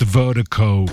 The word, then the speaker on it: Virdiko: And Vertigo is spelled V-I-R-D-I-K-O. That's V-I-R-D-I-K-O, pronounced Vertigo.